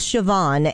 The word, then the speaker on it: Siobhan: So I bring you today a bad joke of the day in her honor, because her name is Siobhan and it is the Gaelic spelling.